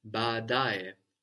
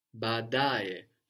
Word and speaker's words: baadae: That's B-A-A, and you pronounce both, D-A-E. And we've seen already in two examples, like njo, come, the order, and also I say, no, I say, gone into Swahili, that when we have two vowels together, they count like two syllables, no? So we have the accent on the penultimate one, on the da, badae. But we still have two at the beginning, so we pronounce both A's at the beginning, but the da is still accented.